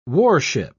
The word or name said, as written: warship